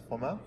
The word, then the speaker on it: Flamel: Flamel? – I think so, yes.